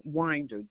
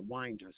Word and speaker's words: Winder: Donna Winder, W-I-N-D-E-R, Winder, spelled like Winder. – Donna, D-O-N-E-A-N? – D-O-N-N-A.